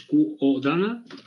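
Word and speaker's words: Qodana: Ahora JetBrains ha sacado Codana, que no sé cómo se pronuncia, es Codana, todo seguido. La Q de queso, ¿vale? La O y Dana.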